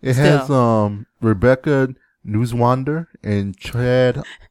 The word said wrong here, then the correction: Neuenswander: No, I don't know who that is, but I doubt that's how you say her name. But you say Rebecca what? Nuzwander? – Yeah, Neuenswander. Neuenswander. Neuenswander.